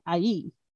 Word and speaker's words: Ai: But they like 3 different times were like, no, it's like Ai. I think that's how it is. I think it's Ai on the, so emphasis on the first one, but they explained it like 3 times.